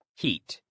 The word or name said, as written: heat